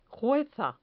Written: jueza

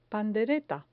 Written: pandereta

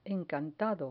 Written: encantado

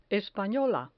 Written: espanola

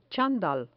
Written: chandal

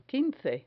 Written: quince

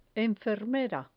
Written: enfermera

